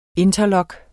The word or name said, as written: interlock